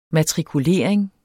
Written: matrikulering

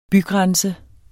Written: bygrænse

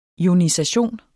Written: ionisation

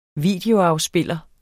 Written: videoafspiller

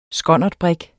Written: skonnertbrig

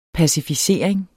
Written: pacificering

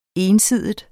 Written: ensidig

